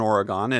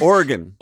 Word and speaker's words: Oregon: Yeah, you got to give respect to you. That's how people from Oregon say it. It sounds weird, but that's how they say it. – Oregon. – Oregon.